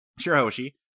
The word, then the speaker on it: Shirahoshi: Princess Shirahoshi? Shirahoshi? Was that how you pronounce her name? – Shirahoshi.